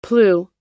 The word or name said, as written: plew